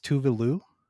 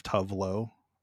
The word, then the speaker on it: Tove Lo: It's Tuvalu. – Huh. – Because that's like the Swedish pronunciation, I guess, is Tuvalu. And like she adopted the American pronunciation of Tuvlo. as like it like a thing because it like she was fine with it or whatever just to and she says she uses it herself